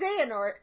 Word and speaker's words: Xehanort: Xehanort. – Xehanort. – Xehanort. – It's Xehanort.